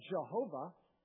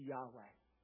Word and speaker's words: Yahweh: And despite the popularity of this pronunciation at one time, it's largely understood to be a mispronunciation, and most scholars believe the most accurate way to pronounce it would be Yahweh.